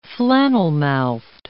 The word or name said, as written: flannelmouthed